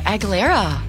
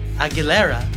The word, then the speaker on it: Aguilera: Oh, yeah, Aguilera. – Aguilera.